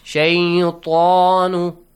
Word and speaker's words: الشيطان: You should join it with Sheen. Because Sheen is sun letter and you skip Lam also. And Hamzatul Wasl also. – Yes. – Shaytan.